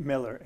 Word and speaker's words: Mellor: Now, well, first off, could you spell that last name, John Mailer? Is that Miller? M-E-L-L-O-R. – Okay, M-E-L-L-O-R.